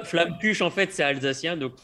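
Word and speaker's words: Flammekueche: La deuxième partie, on l'a nommée Flamme-Cuche. Est-ce qu'on prononce comme il faut? – Ouais, c'est en français, ouais.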